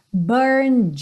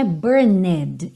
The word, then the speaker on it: burned: Lembra que não é burned, embora tenha essa letra E, ela não é pronunciada, por isso está pintadinha de cinza aí. Então você diria burned, burned, parando no D.